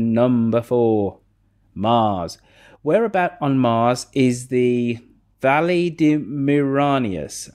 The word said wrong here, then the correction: Valles Marineris: Am I pronouncing that halfway correctly? – Valles Marineris.